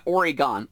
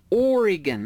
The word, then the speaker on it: Oregon: Not Oregon, Oregon. That's how they say it in Oregon.